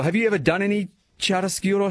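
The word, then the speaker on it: chiaroscuro: I just remember Chariscuro. – Beautiful pronunciation, Rosie.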